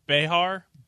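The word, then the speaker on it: Bexar: I think the technical... like the correct way to say it is Behar, but everybody says Bear.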